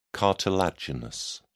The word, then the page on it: cartilaginous: British English